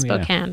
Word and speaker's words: Spokane: Spokane.